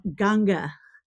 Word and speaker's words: Ganga: What is the Indian pronunciation for it? – Ganga. – Ganga. Okay.